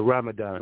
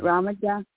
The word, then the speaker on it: Ramadan: Yeah, Ramadan. – Ramadan, yes.